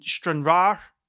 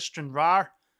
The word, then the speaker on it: Stranraer: Someone gave me trouble for not pronouncing the R at the end of Stranraer. I used to say Stranraer, and folk would be angry.